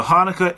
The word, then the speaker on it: Hanukkah: It's either Hanukkah, H-A-N-U-K-K-A-H, or pronounced Chanukah with C-H first.